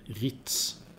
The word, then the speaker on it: Rietz: Men sen kom Dyritz, R-I-E-T-Z. Och då sa han, hur fan uttalar du det egentligen? Riet, okej, okay. Så hur man skriver det inom parentes, uttalas Riet, hur man nu får det till det. Duriet, Ritz skulle jag vilja säga.